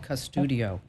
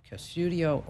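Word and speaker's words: Qustodio: Another one is called Custudio, and it's spelled with a Q, Q-S-U-D-I-O, Custudio or Bark.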